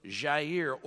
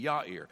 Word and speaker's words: Yair: And after him arose Jair, or you may say Yair. Probably the Hebrew pronunciation would be Yair.